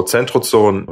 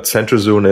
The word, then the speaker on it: centrozoon: So centrozoon, that's the German pronunciation, but centrozoon is uh,